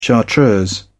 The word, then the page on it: chartreuse: British English